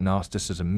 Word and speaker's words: Gnosticism: but what Gnosticism means. Think like this silent G, it starts with an N, but it's got a G in there.